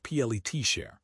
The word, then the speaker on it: Pletcher: Todd Pletcher, pronounced PLET share, are the only ones who can find top-tier talent.